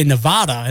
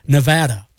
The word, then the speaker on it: Nevada: The next toughest state to pronounce, kind of surprising, Nevada, because people like to say Nevada instead of the preferred Nevada, apparently.